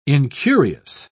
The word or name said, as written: incurious